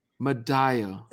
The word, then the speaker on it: Madaio: You did not. That is okay. It is a 9 times out of 10, it's wrong. So it's Madaio. – Madaio.